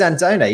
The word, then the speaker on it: Andone: I looked up the Romanian pronunciation, and it is Andone, even without the accent on the E.